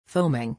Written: foaming

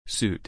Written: suit

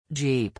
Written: jeep

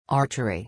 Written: archery

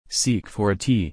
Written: seek for a tea

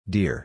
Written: dear

